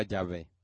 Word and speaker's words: Yahvé: No sabemos exactamente cómo se pronuncia porque nada más hay cuatro consonantes en el texto hebreo, el tetragramatón para esas cuatro letras. Pero pensamos que se pronunciaba Yahvé.